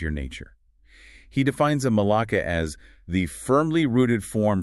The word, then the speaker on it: malaka: He calls it a malaka. That's pronounced M-A-L-A-K-A.